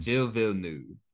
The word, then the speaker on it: Gilles Villeneuve: Gilles Villeneuve.